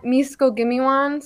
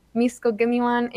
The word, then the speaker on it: Miskogihmiiwan: So all my social medias are at Misko Gimiwan.